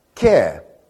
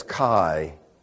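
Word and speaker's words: Ke (καί): Ke. Now, if your pronunciation comes straight out of Bible school, it's kai. It's ke in Greek. It's kai in not so good Greek.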